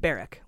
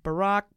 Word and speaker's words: Barrack: Tom Barrack's name is pronounced Barrack, like a military barrack. We were like, Barack, Barrack, Borat?